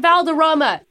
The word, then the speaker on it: Valderrama: Wilmer Valderrama.